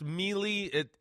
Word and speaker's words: Miele: And this morning we had this Miele, M-I-E-L-E, Miele, I believe is how you say it.